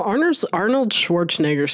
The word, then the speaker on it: Arnold Schwarzenegger: Well, Arnold Schwarzenegger still has a, I know that's how you pronounce it. It doesn't sound right though.